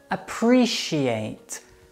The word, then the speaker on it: appreciate: It's appreciate. Appreciate. She ate all the food. Appreciate. She ate. Appreciate. So the C in this word is pronounced exactly like an SH.